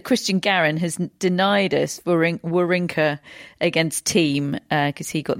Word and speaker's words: Wawrinka: Yes, I am pronouncing it Wawrinka. Apparently, we've been pronouncing his name wrong for the past 15 years.